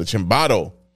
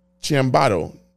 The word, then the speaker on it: ciambotto: It was the ciambato, which is sausage, pepper, Italian sausage, bell peppers, tomatoes, potatoes. – I think you pronounced an A in there when it's an O. – Ciambato.